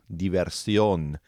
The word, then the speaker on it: diversión: And remember, we can learn two, we know two things about a word when it ends in I-O-N in Spanish. And those two things are, they're feminine, and also there's an accent on the O, diversión.